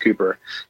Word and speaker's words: Cowper: Like William Cowper, which is spelled Cowper for anybody who wants to Google it. It's Cowper, but it's pronounced Cooper.